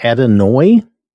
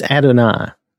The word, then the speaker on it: Adonai: That looks like Adonai. I don't know why that is. I've never heard it pronounced that way. I've always heard it pronounced Adonai.